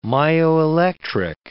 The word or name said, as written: myoelectric